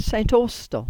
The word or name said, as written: St Austell